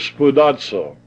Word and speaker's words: spoudazo: The Greek word for endeavoring is spoudazo. And it's spelled S-P-O-U-D-A-Z-O, spoudazo.